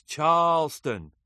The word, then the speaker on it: Charleston: Nigel, with his British accent, had successfully removed the R from his diction, but there was a certain emphasis on the first syllable that he just wasn't getting. "It's Charleston," said the native Charlestonian.